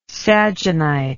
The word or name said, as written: sagenite